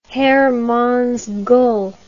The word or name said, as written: heermanns gull